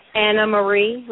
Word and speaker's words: Anna Marie: Anna Marie, like Anna and Marie, but together.